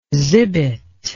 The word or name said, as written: zibet